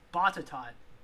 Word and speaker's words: Botetourt: And then there was a um, there was another uh, incident that happened around the same year in Botetourt. Uh, I guess that's how you say it in Vir- Botetourt. That's how you say it uh, in Virginia um, but we can skip over that because I don't have to read the whole thing.